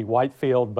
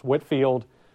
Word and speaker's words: Whitefield: And I know the way it's spelled, it looks like it would be Whitefield, but I'm on good authority, I'm told it was pronounced Whitefield.